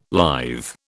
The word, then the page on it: lithe: British English